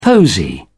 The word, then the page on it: posy: British English